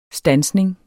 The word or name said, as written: STANSNING